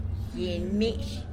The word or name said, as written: gyiny mish